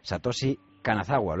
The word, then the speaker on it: Satoshi Kanazawa: Y es que durante este verano ha sido noticia el número diario de una investigación llevada a cabo por el polémico psicólogo evolutivo Satoshi Kanazawa. ¿Lo he dicho bien? – Perfectamente.